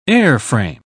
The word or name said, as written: airframe